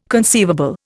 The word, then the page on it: conceivable: Indian